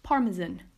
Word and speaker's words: Parmesan: Parmesan, Parmesan. It has three syllables, par, par, par, m, m, the schwa sound, parme, and we end it with a z, zen, Parmesan, Parmesan, Parmesan.